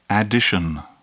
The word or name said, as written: addition